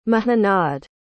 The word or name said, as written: muhunnad